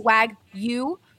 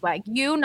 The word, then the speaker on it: Wagyu: Hassel Cattle Company is the absolute best source for farm-to-table Wagyu, we found out today that it's Wagyu, not Wagyu. Beef in the country.